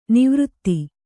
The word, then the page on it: ನಿವೃತ್ತ: nivřtta